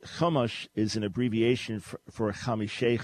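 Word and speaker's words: chumash (חומש): There is no ch sound in Hebrew, it's a ch. So Robert's question, it's not chumash, but chumash.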